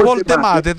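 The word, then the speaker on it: Woltemade: Voldemade, sì, è semplicissimo. Voldemade tedesco, mi sono informato, Voldemade, si pronuncia così. – Perché ieri lo pronunciavo in inglese io.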